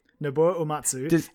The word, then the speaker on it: Uematsu: Nobuo Uematsu, and he didn't directly compose it, thank you.